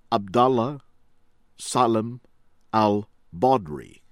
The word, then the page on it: Abdalla Salem Al-Badri: American English; from Libya